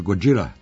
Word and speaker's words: Gojira: Apropo Tool, od grupe Tool, Julija predlaže Gojira, odnosno Godzilla, ili kako već kažu, Gojira, kako to Japanci izgovaraju, pa oni su se zvali Godzilla valjda na početku karijere, pa su promenili ime.